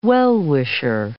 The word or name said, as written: well-wisher